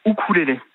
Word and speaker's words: ukulélé: Alors, normalement, on dit ukulélé. Le U en océanien se prononce « ou ». Bon. Donc normalement, on devrait dire « ukulélé ». C'est okay. La prononciation anglo-saxonne du mot.